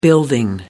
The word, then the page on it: building: American English